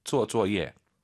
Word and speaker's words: 做作业: 쪼쪼예. 쪼쪼예. 그죠? 쪼쪼 댄스가 아니라 쪼쪼. 쪼쪼예. 이거 기억하시면 됩니다.